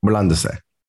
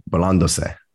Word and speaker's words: burlándose: S-E is se. – Like burlándose. Burlándose. – Burlándose. Yep. – How do you spell that? B-U-R-L-A-N-D-O-S-E. – Burlándose.